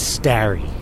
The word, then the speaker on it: Starry: No, it's Starry. This rhymes with Larry.